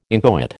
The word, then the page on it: inchoate: American English